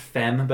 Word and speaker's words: F3M: And it's by, I'm guessing it's pronounced Femme, but it's spelled F3M.